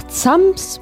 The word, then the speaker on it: Zams: Und zwar ist unser Ziel nach Zams bei Landeck.